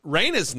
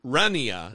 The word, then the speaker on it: Raina: Remember how long I mispronounced Raina's name? – Of course. – Because it looks like it's spelled in my stupid brain, Rania, mm-hmm.